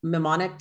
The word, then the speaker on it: mnemonic: Like, she kept trying to remember I'm not going to say it right. Is it a mnemonic device? – Oh yeah. Yeah. Mnemonic.